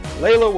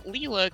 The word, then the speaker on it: Layla: Did I pronounce that right? – Yeah, actually, that's why that big smile, because I was like, oh my god, he actually said it right. Everybody else is like, Leela, because it's spelled like Sheila, but, yeah.